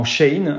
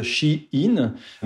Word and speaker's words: Shein: C'est à cette époque que le nom a été raccourci en Shane, alors qu'il faut prononcer du coup à l'anglaise She-In.